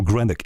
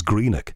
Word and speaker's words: Greenock: And I know, you know, most places in Scotland, and I can pronounce them, Kirkcaldy, and there is a place called Greenock, which a lot of people in the south call Greenock. It is Greenock.